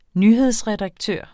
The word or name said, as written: nyhedsredaktør